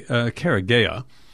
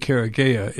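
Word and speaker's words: Karegeya: We want to ask him about this recent the January 1st assassination of Colonel Patrick Karagewa. And I think I mispronounced that. I'm sorry. Karagea. And so Patrick Karagea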